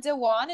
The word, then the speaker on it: Dewan: So Jenna Dewan, is that how you say her name? – Yeah.